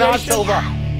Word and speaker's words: Shana Tova: And let's close by wishing everybody Shana Tova. – I love when it comes from you. – Did I do okay on that? Pronunciation? – Shanah Tova.